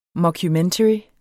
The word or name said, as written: mockumentary